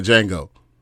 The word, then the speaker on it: Django: He was like, Django. He's like, you know, he was talking about the J is silent and all that.